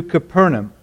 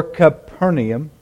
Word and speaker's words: Capernaum: In verse 21, it says, they, meaning Jesus and these four followers, these four men, Peter and Andrew, James and John, they went to Capernaum, or Capernaum. You can pronounce it either way.